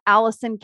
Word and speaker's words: Alison: It's Alison Cady Coaching.